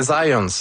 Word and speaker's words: Zajonc: c'est Zajonc. – Ouais? OK. – J'ai-tu pas dit Zajonc? – Ben, j'avais dit plus Zajonc, mais... – Ah, ben, j'ai prononcé un petit peu à l'anglais. J'ai prononcé mon polonais à l'anglais.